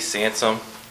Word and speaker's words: Sansom: Yeah, my name is Eddie Sansom, S-A-N-S-O-M. – Address 13102 Kimbrough. – Could you spell that? – K-I-M-B-R-O-U-G-H.